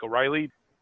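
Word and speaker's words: O'Rahilly: Pronounced more like O'Reilly.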